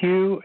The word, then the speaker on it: Hugh: Hugh, H-U-G-H. The last name, Paulson, is spelled T like Tom, R-A-U-L-S-E-N like Nancy.